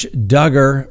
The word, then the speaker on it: Duggar: Josh Duggar rhymes with hugger.